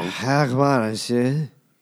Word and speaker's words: Hogmanay: Hogmanay.